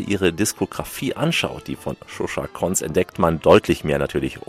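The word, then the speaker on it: Koncz Zsuzsa: Shusha Konz, die Schreibweise, die erfordert Konzentration, will sie gar nicht genau buchstabieren, aber Shusha Konz, so kann man es aussprechen.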